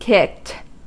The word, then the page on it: kicked: American English